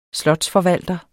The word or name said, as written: slotsforvalter